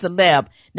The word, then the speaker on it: célèbre: It's C-E-L-E-B-R-E, but it's pronounced célèbre.